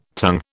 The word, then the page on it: tongue: British English